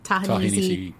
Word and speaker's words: Ta-Nehisi: Ta-Nehisi Coates.